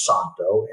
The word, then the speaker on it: Zanto: The name of the brand is Santo, as Z-A-N-T-O. The Z is silent.